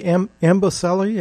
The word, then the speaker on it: Amboseli: Well, your work with the Amboseli, am mm-hmm.